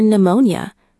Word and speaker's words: pneumonia: For pneumonia. Greek word. Pne was a real sound in Greek. English speakers cannot easily pronounce pne, so we drop the P.